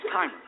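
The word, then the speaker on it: Kaymer: Is it Kamer? Okay, it's Kamer.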